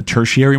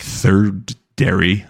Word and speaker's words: tertiary: But now that Taylor Swift's coming, the secondary market, the tertiary market, is that how you say it? Not, you don't say like third dairy.